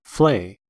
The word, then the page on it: flay: American English